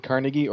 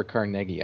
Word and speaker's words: Carnegie: Now, is it Carnegie or Carnegie? I've heard it pronounced both ways.